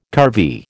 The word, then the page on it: curvy: American English